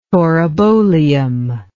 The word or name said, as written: taurobolium